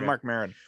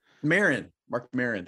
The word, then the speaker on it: Mark Maron: I was just watching a stand-up routine with Mark Morin, the WFT podcast guy. Is that what I say his name? Mm-hmm. Right? – Yeah, Mark Maron, I think. – Mark Maron, thank you.